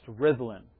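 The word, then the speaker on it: Rhuddlan: So in Welsh, the double D is pronounced like a TH. And while we speak English there, all the road signs are in English and in Welsh. So that's pronounced Rhyddlan.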